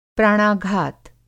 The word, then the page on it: प्राणाघात: Hindi